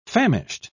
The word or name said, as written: famished